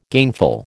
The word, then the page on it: gainful: American English